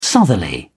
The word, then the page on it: southerly: British English